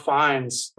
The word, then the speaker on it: Fiennes: Ralph Fiennes.